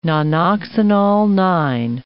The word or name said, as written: nonoxynol-9